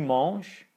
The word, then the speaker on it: manges: Tu manges. So it's the same pronunciation, but tu manges, the last letter of the word mange, is an S here.